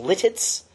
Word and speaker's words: Lititz: A 2014 graduate from CTSFW, he was called as pastor of Mount Calvary Lutheran Church in Lititz. Did I pronounce that right? Thank you.